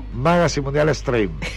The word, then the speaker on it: Magazine Mundial Stream: Magazine Mundial Stream. Así me dijeron que lo pronuncie.